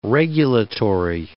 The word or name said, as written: regulatory